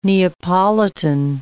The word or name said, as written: Neapolitan